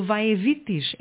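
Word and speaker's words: Vae Victis: do Vae Vitis. É assim que se pronuncia? – Sim.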